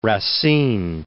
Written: Racine

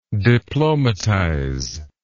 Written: diplomatization

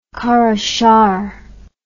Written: Karashahr

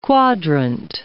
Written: quadrant

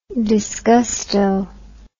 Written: disgusto

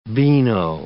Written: beano

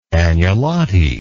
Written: agnolotti

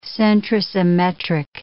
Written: centrosymmetric

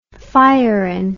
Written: fiorin

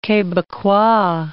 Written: Quebecois

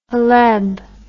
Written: Haleb

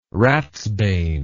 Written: ratsbane

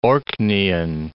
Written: Orkneyan

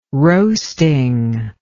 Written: roasting

